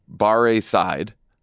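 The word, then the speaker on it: Barre Seid: named Barre Side. Is that how we're pronouncing it? – I mean, is it like a bar class?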